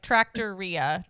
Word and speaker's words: Trattoria: Yeah, like Trattoria.